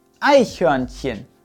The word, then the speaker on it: Eichhörnchen: Und jetzt ist noch ganz wichtig, die erste Silbe wird betont. Es heißt also nicht Eichhörnchen und es heißt auch nicht Eichhörnchen, sondern Eichhörnchen. Eichhörnchen, das Eichhörnchen.